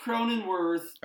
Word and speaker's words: Cronenworth: Cronworth. What's going on? – Jake Cronenworth is...